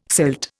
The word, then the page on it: silt: Indian